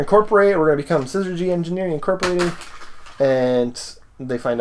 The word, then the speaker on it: Syzygy: Syzygy. – Synergy? Syzygy. – There's no R in there, even though I'm pronouncing it R.